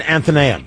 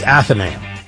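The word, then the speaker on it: Athenaeum: I've always pronounced it Anthonaim. It's not even Anthonaim. It's not with the N. It's Athenaim. I used to pronounce it wrong in Anthonaim. It's Athenaim.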